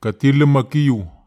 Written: katilum ma kiu

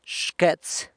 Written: xquets

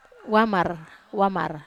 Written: wamar

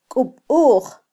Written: kubuj

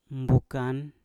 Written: mbukan

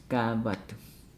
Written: gaavadu

